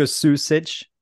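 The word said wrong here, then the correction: Sučić: You just mispronounced his name, probably. – Sucic.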